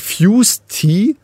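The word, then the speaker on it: Fuze Tea: Du kennst ja bestimmt, ich weiß selber nicht, wie es ausgesprochen wird, ich glaube Fuse Tea, ja. Aber mit Z. – Ja. – F-U-Z-E ja. Und dann Tea, also wie das englische Tee.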